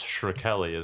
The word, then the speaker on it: Shkreli: Martin Shrekey. – It's Shkreli, isn't it? – Shkreli. – Shkreli. – Shkreli? Is that how you say it? – Yeah.